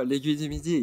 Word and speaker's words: L'Aiguille du Midi: But other than that, I love the terrain, because one, like my favorite zone is, I think the Aguimadi, if I say that right. But, L'Aiguille. – What? Say it. – Yeah. No, L'Aiguille du Midi. Yeah, that was good. – That was good. Perfect. Yeah.